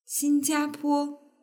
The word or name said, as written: xinjiapo